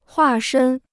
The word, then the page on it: 化身: huà shēn